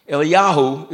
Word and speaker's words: Eliyahu: There is no J in the Hebrew language. So when you see Jeremiah and Elijah, etc., etc. Cetera. that doesn't exist for the Jews. Eliyahu is how you say Elijah, right?